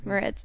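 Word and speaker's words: Moretz: There's a good okay, it's a decent a decent sketch where um, Chloe Grace Mertz is that how you say her name? – Moritz.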